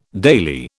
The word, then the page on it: daily: British English